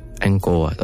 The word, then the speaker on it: Angkor Wat: It's A-N-G-K-O-R. – A-N-G-K-O-R. – Angkor. And then Wat, W-A-T. – Angkor Wat.